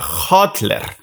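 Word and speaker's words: hodler: Se pronuncia hotler.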